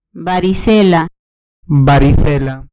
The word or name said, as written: varicela